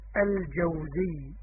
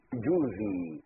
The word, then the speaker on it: الجوزي: لو قرأ أول الكتاب لعلم أن النطق الصحيح الجوزي بفتح الجيم وسكون الواو، الجوزي. ولكن الجاهل يقول لابن الجوزي، جوزي.